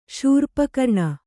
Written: ಶೂರ್ಪಕರ್ಣ

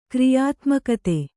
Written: ಕ್ರಿಯಾತ್ಮಕತೆ

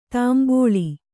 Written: ತಂಬಳಿ

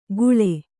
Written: ಖೂಳೆ

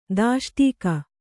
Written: ದಾಷ್ಟೀಕ

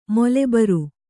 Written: ಮೊಲೆಬರ್

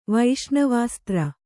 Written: ವೈಷ್ಣವಾಸ್ತ್ರ

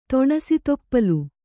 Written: ತೊಣಸಿತೊಪ್ಪಲು

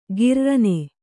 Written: ಕಿರ್ರನೆ

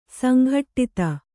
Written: ಸಂಗಡಿತ

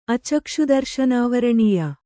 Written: ಅಚಕ್ಷುದರ್ಶನಾವರಣೀಯ

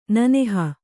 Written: ನೆನಹು

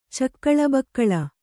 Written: ಚಕ್ಕಲುಬಕ್ಕಲು

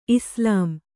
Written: ಇಸ್ಲಾಂ

